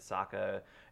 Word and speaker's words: Sokka: Sokka, it rhymes with Akka.